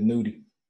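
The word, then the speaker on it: Nudy: How do you say it? Nutty or Nudie? – I think it's Nudie.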